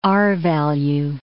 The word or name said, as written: R-value